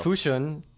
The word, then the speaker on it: Fushun: Fushun, Liaoning province.